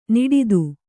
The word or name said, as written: ನಾಡಿದು